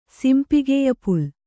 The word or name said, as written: ಸಿಂಪಿಗೆಯಪುಲ್